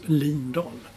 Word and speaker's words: Lindahl: Och enligt vår vän Olof Lund så påstår han att det var hon själv som har sagt att hennes namn ska från och med nu uttalas Lindahl.